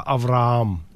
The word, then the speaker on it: Авраам: Авраам, но поскольку при переводах с еврейского на греческий этот буква «гей» невозможно передать, поэтому мы стали произносить это просто «Авраам». – Удваивая букву «а», да? – Да, да. Да. Это выглядит так, как будто бы буква «а» удвоилась. На самом деле там вставилась целый звук «гей», а поскольку в семитских языках согласные звуки не могут существовать отдельно, к ним должна быть повешена какая-то огласовка на них, угу.